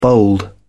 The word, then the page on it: bold: British English